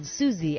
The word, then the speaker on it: Susie: My name is spelled Susie, S-U-S-I-E.